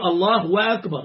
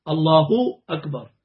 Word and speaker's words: الله أكبر: حتى في النطق لو اقول الله واكبر غلط، الله اكبر يعني ضم لها.